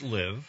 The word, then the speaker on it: LIV: You know what L-I-V actually stands for? I haven't the slightest idea. – They pronounce it live.